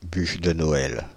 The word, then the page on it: bûche de Noël: France (Île-de-France); /byʃ də nɔ.ɛl/